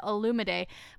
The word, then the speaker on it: Olumide: Okay, and Olumide, that is how Michelle pronounced his name at the very end of the episode. I like rewound and made sure that I got it. I wrote it down correctly. She said Olumide, but then on his, like, when he came out of the limo, they just wrote his name as Olu.